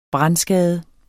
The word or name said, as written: brandskade